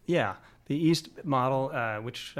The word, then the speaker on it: E4ST: And you use a tool, that you've developed called, I think it's pronounced the EAST model, but there's a 4 in there.